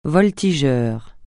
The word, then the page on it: voltigeur: francais